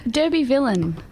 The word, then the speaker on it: Derbyvillian: How is the correct way to pronounce that? – Derbyvillian. – Okay. – Like the word villain.